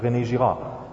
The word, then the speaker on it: René Girard: Sometimes pronounced here René Girard or whatever, but René Girard.